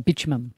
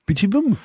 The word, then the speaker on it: bitumen: So apparently bitumen ends in an N, not an M.